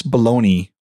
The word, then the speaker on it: bologna: How can we pronounce baloney when it's spelled Bologna?